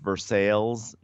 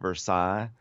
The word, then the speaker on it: Versailles: And we really had no way of knowing that Versailles, Kentucky, is pronounced Versailles, not Versailles.